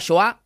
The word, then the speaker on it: Shoah: Y por ejemplo, cuando hubo una publicación que condenaba al diputado Nicolás Massot porque banalizó la Shoah, así se pronuncia, María, ella respondió, qué cansada me tienen estos hijos de puta.